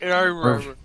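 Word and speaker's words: Meruru: Maruru is how you pronounce it.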